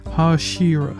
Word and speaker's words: Hashira: Har Harishas. No, that's not how you pronounce it. I always forget how do you pronounce these guys. Hashira, Hashiras.